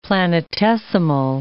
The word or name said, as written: planetesimal